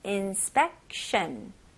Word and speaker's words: inspection: 여기서 in, spec, 그 다음에 shun. 네, T-I-O-N 이렇게 S-H-U-N 느낌이 많이 나죠? shun, inspection, inspection.